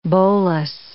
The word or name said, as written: bolus